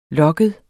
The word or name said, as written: lokket